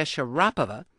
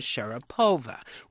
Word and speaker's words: Sharapova: All you letter writers noted that most sportscasters, including those covering Wimbledon, were pronouncing her name Sharapova. Well, actually, the name is correctly pronounced in Russian with the accent on the second syllable.